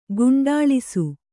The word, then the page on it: ಗುಂಡಾಳಿಸು: guṇḍāḷisu